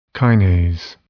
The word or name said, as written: kinase